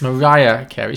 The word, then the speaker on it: Mariah: Did you just pronounce her Maria Carey? – I did, yes. – Oh, come on. – I was... very 50-50 on it. I didn't know. – It's Mariah Carey.